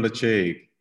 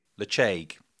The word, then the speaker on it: Ledaig: You're looking for a Lecheig. – Lecheig. So LEDAIG for the uninitiated. – Just to kind of confuse things, it's spelled LEDAIG.